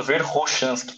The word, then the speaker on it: Verkhoshansky: às vezes você vê eu falando Verkhoshansky, tá? que é a, é a pronúncia do nome em russo.